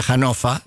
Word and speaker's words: Hannover: Había un restaurante en se escribe Hannover, se pronuncia Hannover en alemán.